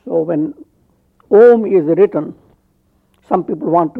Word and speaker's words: Om: They start saying, ah, oo, but it's not so. It's just Om, A and U blend into O. And ends with M sound.